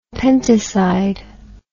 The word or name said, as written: pentoside